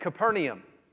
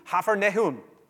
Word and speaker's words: Capernaum: Here's an easy one, right? Capernaum. That is not at all how we're supposed to pronounce that. We get to the city, the guide is there with us, and he says, "Welcome to Hafernehum." And I said, "What?" That's how it's Hafernehum.